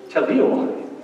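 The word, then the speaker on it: Tetliomai (τετελείωμαι): The Greek word for perfect is tetliomai, or tetliomai, I pronounced it wrong. Tetliomai, T-E-T-L-E-I-O-M-A-I, tetliomai.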